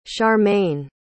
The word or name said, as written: charmaine